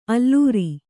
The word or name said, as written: ಅಲರ್